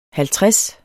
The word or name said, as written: halvtreds